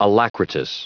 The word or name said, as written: alacritous